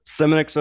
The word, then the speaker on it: Semonick: Semonick's okay. It's a lot better than what I've heard, but it's Semonick.